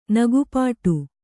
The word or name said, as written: ನಗುಪಾಡು